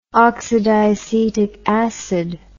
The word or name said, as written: oxydiacetic acid